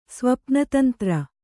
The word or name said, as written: ಸ್ವಪ್ನತಂತ್ರ